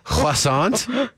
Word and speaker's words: croissant: silent T will always get you. – Croissant. – Croissant. – Croissant. – Croissant. You don't, not the T at the end either.